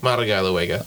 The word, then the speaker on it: Madalagawega: Is that right? – Madalagawega. – I didn't pronounce the G hard enough.